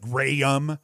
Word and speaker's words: Jrayum: Luke Graham, and it's spelt weird, J-R-A-Y-U-M, so it's like Graham, 2 goals.